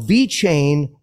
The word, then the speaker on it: VeChain: Follow VeChain Official on Twitter to learn how to be involved in this exciting new era of technology.